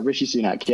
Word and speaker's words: Rishi Sunak: Is it Rishi Sunak? Is that correct? – Yeah, Rishi Sunak, yeah.